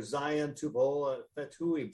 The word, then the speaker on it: Zion Tupou-Fatui: Well, Arizona may have negative points this week because the Huskies are getting linebacker Zion Tupou-Fatui back.